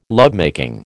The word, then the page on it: lovemaking: American English